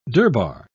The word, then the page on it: durbar: British English